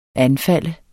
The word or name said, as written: anfalde